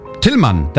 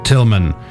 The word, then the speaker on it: Tilman: Hi, my name is Tillmann. What? Tillmann, that's my name. How do you spell that? T-I-L-M-A-N. Ah, Tillmann. Ja, genau, Tillmann.